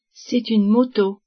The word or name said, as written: moto